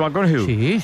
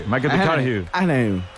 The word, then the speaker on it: McConaughey: ¿Matthew McConaughey? – Sí. – Que hay que ir corrigiendo la pronunciación. – McConaughey. Matthew McConaughey.